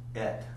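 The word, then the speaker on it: et (עת): And what it says there, for the time, the word for time down there is et. It's E-T. It's got a long E, so it's got the A sound to it. Et is what the way you pronounce it.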